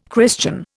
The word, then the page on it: Christian: Indian